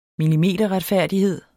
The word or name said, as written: millimeterretfærdighed